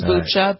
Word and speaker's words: Bucha: The mayor of one of the cities, I think it's Bucha, is that how you pronounce it? B-U-C-H-A? – I think it's Bucha, but yeah, Bucha.